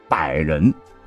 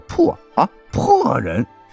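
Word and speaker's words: 柏人: 结果呢，刘邦在经过途中百人县，晚上要下榻此地唯一一座高级宾馆时，这刘邦就忽然问侍从说：“ 此地何名？” 答：“ 百人。” 柏树的那个柏，可是由于刘邦啊，他是徐州人，口音重啊，就念成了破啊破人，逼迫人的地方，认为大大不吉，就临时赶路去另外的地方入住，使得原本成功率极高的刺杀功亏一篑。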